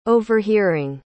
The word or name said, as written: overhearing